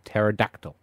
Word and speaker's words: pterodactyl: It's spelt pterodactyl, but it's pronounced pterodactyl.